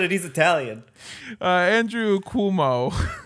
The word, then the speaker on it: Cuomo: Cuomo. It's Cuomo.